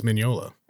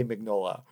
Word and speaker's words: Mignola: If it's Italian, it's Mignola. – I've never actually heard him say it, so I'm taking your word on this. But I have so many friends that say Mignola. – Americans love to mispronounce Italian names and words.